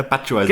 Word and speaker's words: Batshuayi: Jetzt muss ich gucken, ob ich den richtig ausspreche. Batshuayi. – Ja, Batshuayi, genau, so habe ich es immer gelernt.